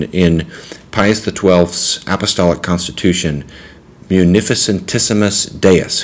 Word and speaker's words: Munificentissimus: So I do it because it's seven syllables, so you break it down into four and three. You gotta love Latin. Latin is the coolest language. Munificentissimus.